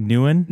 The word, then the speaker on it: Nguyen: N-U-G-Y-E-N? – It's like Nguyen, right? Like Nguyen. – N-G-U-Y-E-N. – N-G-U-Y-E-N, yes. It's a very common, yeah. – How do you say it? Nguyen? – Nguyen. – Just Nguyen works.